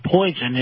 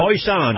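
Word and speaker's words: Polson: Not quite to Poison, as it might be, pronounced. – Poison, you had it right the first time.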